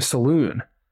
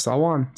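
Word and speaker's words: Salaün: I know Salwan is definitely the correct. Because okay. People, everyone in the league pre-draft, even some people in Charlotte, were calling him Saloon. I was looking at his spelling. I was like, why is everyone saying Saloon? And then the league sent out their pronunciation guy like that week, and it was Salwan.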